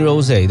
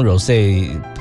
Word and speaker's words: Rosé: 我之前都念 Rosé 对不对，但是我发现好像 Rosé 才是正确的发音，没关系，知错能改，赶快调整。